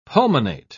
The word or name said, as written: pulmonate